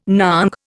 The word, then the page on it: nong: Indian